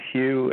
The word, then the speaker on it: Hugh: But if you just Google My name, Hugh, H-U-G-H. Charlson is spelled T like Tom, R-A-U-L-S-E-N like Nancy, and then put Facebook profiles.